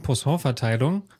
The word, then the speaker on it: Poisson-Verteilung: Also dieses Wort, was ich mal nicht aussprechen kann, Poisson-Verteilung, habe ich das jetzt richtig ausgesprochen? – Das war ziemlich gut, glaube ich, ja.